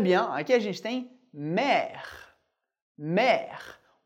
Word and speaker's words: mer: O R a gente pronuncia, o E que vem depois a gente não pronuncia, o R da garganta que a gente vai ver em outra aula, e o E accent grave, sempre bem aberto, por isso, mer. Mer.